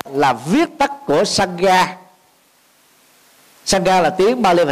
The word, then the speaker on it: Sangha: Ví dụ như tiếng Pali nó gọi là Sangha, thì người Việt Nam mình có thể phiên âm đọc ý dịch là Sangha, còn người Trung Quốc không thể đọc là Sangha được mà phải là tăng già.